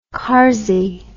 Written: karzi

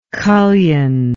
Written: cullion

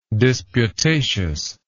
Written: disputatiousness

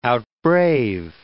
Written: outbrave

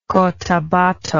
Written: cotabato

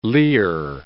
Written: Lear

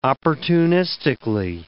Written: opportunistically

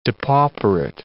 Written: depauperate

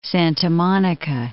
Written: Santa Monica

